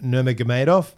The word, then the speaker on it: Nurmagomedov: He was the co-main event to Justin Gaethje and Khabib Nurmagomedov.